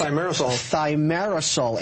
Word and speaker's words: thimerosal: Thimerosal in vaccines.